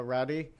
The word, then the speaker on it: Roddy: That's correct. It's Roddy. When I was younger, the name was mispronounced as Rody or Rudy. But when Rowdy Roddy Piper became more popular, people started pronouncing my name correctly more often.